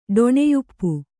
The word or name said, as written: ಡೊಣೆಯುಪ್ಪು